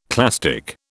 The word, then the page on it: clastic: British English